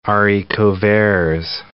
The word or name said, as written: haricot verts